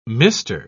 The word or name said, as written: mister